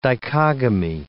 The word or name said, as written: dichogamy